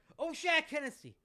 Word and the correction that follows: O'Shaughnessy: Principal O'Shaughnessy.